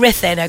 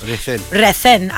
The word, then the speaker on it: Ruthin: Now, that's in Rhythin, okay? – Rhythin. – And I only found out you pronounce Rhythin and not Ruthin not too long ago.